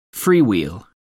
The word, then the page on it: freewheel: American English